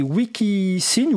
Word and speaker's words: Wikisign: Alors, je vais prononcer Wikisign parce que sa s'orthographie en anglais, W-I-K-I-S-I-G-N.